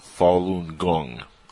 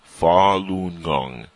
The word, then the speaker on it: Falun Gong: Is a spiritual discipline in the Buddhist tradition, and it's pronounced Falun Gong. I probably mispronounced it there a couple times. Falun Gong.